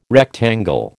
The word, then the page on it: rectangle: American English